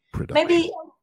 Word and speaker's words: production: Maybe the only complication with that word would be the pronunciation, because in Spanish we say producción, putting the stress in the last syllable, you know. So production, you said it, yeah, you put the stress on the second one.